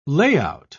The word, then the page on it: layout: British English